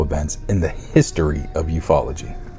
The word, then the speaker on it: ufology: One of the best documented and certainly most witnessed UFO events in the history of ufology.